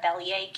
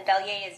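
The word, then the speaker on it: Belyea: And my name is Lisa Bellier Campbell. So I can spell it's Lisa, and then Bellier is B as in boy, E-L-Y-E-A. And Campbell is C-A-M-P-B-E-L-L, which is my full name.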